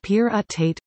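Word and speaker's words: pyrrhotite: Mikichik is partnering with Toronto-based BackTech Environmental on assembling a pyrohotite, pronounced pyr-ut-tate,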